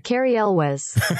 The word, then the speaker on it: Cary Elwes: Cary Elwes.